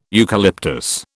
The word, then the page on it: eucalyptus: British English